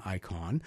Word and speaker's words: IY3KON: It is spelled IY3KON, Icon, who we have featured on Bleeding Cool before.